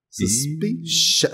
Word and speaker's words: Suspicion: Suspicion ou Suspicion? Espera aí. Suspicion. Suspicion, falei certo.